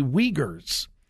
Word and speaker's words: Uyghur: I don't know a whole lot about the Uyghurs, and it's spelled U-Y-G-H-U-R, but I understand that it is pronounced Uyghur.